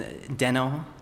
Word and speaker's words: Deno: But it's Deno, so you put the emphasis on the first syllable.